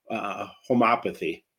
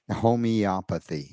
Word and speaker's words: homeopathy: First, I'm sure I'm pronouncing it wrong, so you can correct me, but define homeopathy. – Homeopathy, yes.